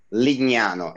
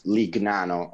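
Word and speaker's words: Lignano Sabbiadoro: So I'll say for the public that it's pronounced Legnano and not Lignano as usual it's being called.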